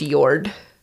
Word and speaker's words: Jord: J. Y is in yellow, so Yord, like J-O-R-D, is pronounced Yord.